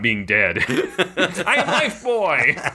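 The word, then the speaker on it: Lifebuoy: Again, I always pronounced it Bowie. – It's Life Boy.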